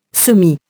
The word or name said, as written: semi-